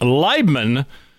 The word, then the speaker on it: Leibman: My special guest with me today is Pete Leibman.